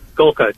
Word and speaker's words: Golka: Is it Golka? Is that how you say his name? – Golka. That's correct. Yep.